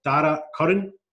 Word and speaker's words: Dara Curran: So episode 32 of the podcast, we've got Dara Curran. I've said that right, Dara, eh? – You've nailed it, mate. Yeah, that's a strong old accent you boys have, so you've done that right.